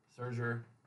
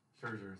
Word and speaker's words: Scherzer: But I am proud of our signing of Scherzer. Scherzer, sorry. Said it wrong.